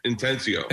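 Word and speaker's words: Atencio: The director is Peter Antisio. Is that how it's pronounced? – Peter Intensio.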